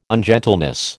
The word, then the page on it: ungentleness: American English